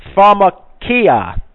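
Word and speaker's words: pharmakeia: And very, very, very curious, in the Greek, we have, the word is pronounced pharmakia, which means pharmacy.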